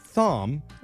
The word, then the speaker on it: Thom: We've got an interesting situation on our hands here with one of our listeners, Thom, spelled like Tom, but with an H in there.